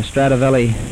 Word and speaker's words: Stradivari: We went to the NBC broadcast of the Stradivari, it's pronounced, violins.